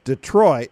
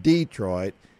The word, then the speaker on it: Detroit: It's first of all, it's Detroit, not Detroit, and it's pecan, not pecan.